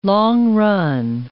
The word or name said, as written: long-run